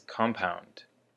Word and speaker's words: compound: So the first word is compound. Compound. And for the word compound, the emphasis is on the first syllable, on the com.